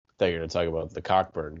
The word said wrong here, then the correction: Cockburn: guy. – His name is Kofi Coburn. – Yeah, it's pronounced.